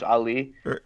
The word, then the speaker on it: Ali: But Ali is very easy to say. So I just like to say, just call me like the boxer, Muhammad Ali. Just call me Ali.